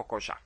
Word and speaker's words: Kojak: Mas eu queria dizer a pronúncia, como é que se pronuncia corretamente a série Koyak. Koyako Kojak.